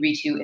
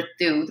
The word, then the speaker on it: Ritu: They're calling me Ritu. It's wrong. It felt like nails on a chalkboard. And I essentially had every single human being, including my own family members who were calling me Ritu because I wouldn't let them call me Ritu, the proper pronunciation.